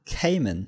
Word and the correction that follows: Kaiman: Kaiman, okay, Entschuldigung.